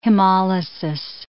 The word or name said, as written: hemolysis